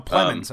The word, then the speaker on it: Plemons: How you pronounce his name? – No, um, Plemons, um, I think. Jesse Plemons. – Plemons, okay. He like he seems to be in the movie I'd prefer watching.